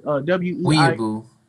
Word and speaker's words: Weibo: Weibo.